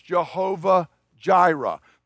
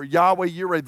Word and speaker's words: Yahweh Yireh: The place where you've heard this Hebrew word before is, as we mispronounce in English, Jehovah Jireh. The Lord is my provider, or Yahweh Yireh, the Lord shall be provided.